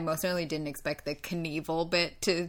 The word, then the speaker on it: Knievel: I don't know why we call it Knievel. It's spelled K-N-I-E-V-E-L. You think it'd be Knievel? Like K-N to start a word? The K, when do you ever say the pronounce the K?